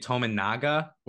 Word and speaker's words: Tominaga: They have one of the best shooters in Tominga. Am I saying that right? I've never said his name right. Is that how you say it? – I believe it's Keisei Tominaga or something Tominaga. – Similar. – Man, I said that way wrong then.